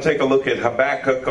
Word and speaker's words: Habakkuk: Some people say Habakkuk. Depends on how you want to pronounce it. I think Habakkuk is pretty good.